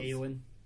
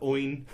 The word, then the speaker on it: Eowyn: Eowyn. You're lucky. – No Eowyns. Not a single Eowyn. Everyone was like Oin. Or Yoin. – The E is silent.